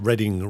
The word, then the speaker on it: Reading: and I've heard somewhere that it's supposed to be pronounced Reading, Writing and Arithmetic.